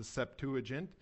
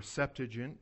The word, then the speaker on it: Septuagint: It's used in the Greek translation of the Old Testament, known as the Septuagint, or Septuagint, wherever, depending on where you're from, you might hear it pronounced that way.